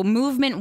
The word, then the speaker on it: MVMT: Give a shout out to Movement Watches, and it's spelled MVMT in case you're trying to Facebook it or Google it or something.